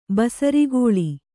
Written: ಬೇಸರುಗೊಳ್ಳು

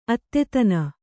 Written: ಅತಿದೀನ

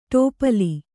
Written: ಟೋಪಲಿ

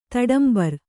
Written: ತಡಂಬರ್

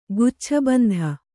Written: ಗುಚ್ಛಬಂಧ

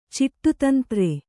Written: ಚಿಟ್ಟುತಂತ್ರೆ

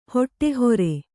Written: ಹುಡಿಹಾರು

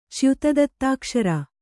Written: ಚ್ಯುತದತ್ತಾಕ್ಷರ

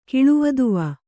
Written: ಕಿಳುವದುವ

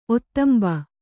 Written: ಒತ್ತಂಬ

